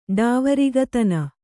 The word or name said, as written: ಡಾವರಿಗತನ